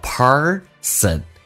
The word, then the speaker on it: person: Every person，美式发音需要重度卷舌，整个舌身往回卷，叫 person。